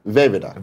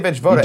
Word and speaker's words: Vejvoda: Vevida. – Vevida. I was going to say Vejvoda. – Don't pronounce the J.